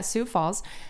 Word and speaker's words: Sioux Falls: Baszler was born and raised in Sioux Falls. Is that how you say it? – Yeah, Sioux Falls, South Dakota.